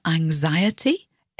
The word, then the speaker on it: anxiety: The noun anxiety, A-N-X-I-E-T-Y, and the adjective anxious, A-N-X-I-O-U-S, can be difficult for English language learners. The trick is that the X is pronounced as a Z in anxiety, and the X is pronounced as a SH, sh, sound in the word anxious, anxiety and anxious.